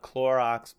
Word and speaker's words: Clorox: She told me Orox the other day, and I said, not Clorox, babe. Oh, I'm so proud of you. You said it right.